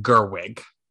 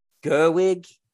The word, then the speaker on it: Gerwig: Sorry, this is embarrassing, but it's pronounced Gerwig. – Gerwig? – Yeah, it's pronounced Greta Gerwig.